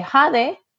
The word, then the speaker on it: Jade: I say, Jade. How you spell that? J-A-D-E.